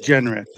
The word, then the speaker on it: Genret: Or is it Genret? – Genret, two syllables.